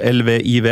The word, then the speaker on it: Lviv: til den ukrainske byen Lviv. Eh, godt uttalt. Ehm, Hvordan ville du sagt det? Nei, altså Det står LVIV, ja. – Ehm, så det ville vært naturlig å kanskje si Lviv.